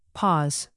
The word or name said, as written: pause